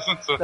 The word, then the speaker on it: Sun Tzu: É assim que fala mesmo? Sun Tzu? – É a primeira pronúncia certa da noite? Não, tá certo, Sun Tzu.